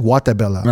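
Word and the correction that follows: Gatúbela: No, Gatubela.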